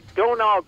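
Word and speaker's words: Domhnall: Donal Gleason is how you pronounce it. It's weird because there's an H and an M in there.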